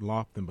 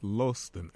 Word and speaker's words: Loston: This first name is spelled L-O-S-T-O-N, not Lofton, but Lawston, L-O-S-T-O-N.